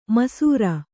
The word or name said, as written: ಮೈಸಿರಿ